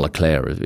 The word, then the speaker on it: Leclerc: She's only new with Ferrari, having come from Sauber last year, where she worked with Charles Leclerc, mm. or Charles Leclerc, if you pronounce it as uh, the French would.